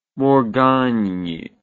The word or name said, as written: morgagni